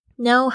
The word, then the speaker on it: know: 好，那我们接着呢就讲到这个 number 2， Know how to say no，知道如何去说不。虽然我这边有两个 no，一个是知道一个是不，他们发音是一样但是拼法不一样。